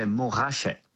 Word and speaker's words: Montrachet: Montrachet, Pouligny Montrachet, which actually in French, we don't pronounce the T, we say Montrachet.